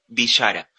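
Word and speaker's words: Vishara: Mi apellido, para los que por ahí no saben cómo se escribe, es B larga I S H A R A Juano Villara. Me pueden encontrar en Spotify, en YouTube, en Instagram, en Facebook, en Twitter. Como no hay otro Juano Villara, es el único que, se van a, a, encontrar ahí.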